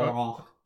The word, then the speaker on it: Laurent: Laurent Lebeau, who's designer Laurent. – Laurent Lebeau. – You did French. You know the last letter is silent.